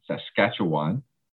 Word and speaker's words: Saskatchewan: and see hear about their work in Saskatchewan. Did I say that right? – Yes.